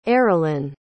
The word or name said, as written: Aaralyn